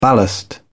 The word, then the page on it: ballast: British English